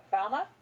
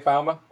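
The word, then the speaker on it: Falme: And how do you say that city, by the way? – What, Falma? – You say Falma? Okay. – Yeah.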